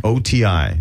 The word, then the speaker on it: OTI: OTI. It's just pronounced OTI. OTI.